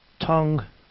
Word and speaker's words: Tonge: Her name is T-O-N-G-E. – Tongue. – Yeah, she is an Aryan, right? – Baroness Jenny Tongue. Yes, she is. – Jenny Tongue.